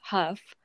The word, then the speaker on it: Hough: So the other big thing that happens is that Dennis Huff, pronounced mm-hmm. Huff but spelled H-O-U-G-H, and it's mm-hmm.